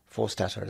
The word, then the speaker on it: Forstater: Maya Forstater is very brave. Is that how you pronounce it? Yes, it is.